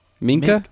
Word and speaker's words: Minkah: Minka? – Minka.